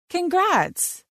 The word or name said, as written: Congrats